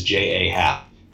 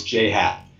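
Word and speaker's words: J. A. Happ: Well, I first I've got a conspiracy theory that you only invited me on to talk about the Blue Jays to see if I would pronounce Jay Happ's name as J.A. Happ. And I'll tell you, I'm wise to you. I know it's Jay Happ.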